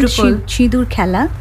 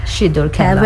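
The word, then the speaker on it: Shidur Khela (সিঁদুর খেলা): The red sindoor khela. Sindoor khela, am I right in saying? So I don't know the Bengali pronunciation. – Yeah, it's called Shidur Khela. – Shidur Khela.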